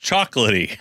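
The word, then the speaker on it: Chocolat: I think Brian told us it's pronounced Chocolaty.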